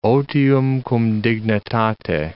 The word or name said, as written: otium cum dignitate